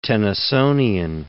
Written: Tennysonian

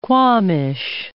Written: quamash

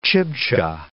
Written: Chibcha

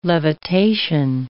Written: levitation

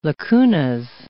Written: lacunas

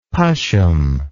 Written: pashm

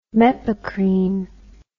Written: mepacrine

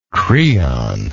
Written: creon

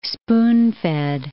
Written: spoon-fed

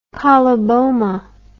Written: coloboma

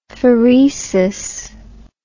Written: phoresis

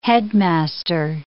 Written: headmaster

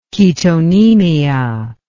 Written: ketonemia

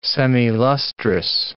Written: semilustrous

